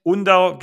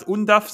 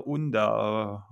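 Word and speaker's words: Undav: Ähm, äh, wie, mir wurde gesagt, ich darf niemals Undaf sagen, das ist auf jeden Fall falsch.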